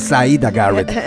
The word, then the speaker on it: Syreeta Garrett: Así es, y bueno, ya extrañaba la pronunciación perfecta que tienes, porque sí es Syreeta Garrett, tienes toda la razón.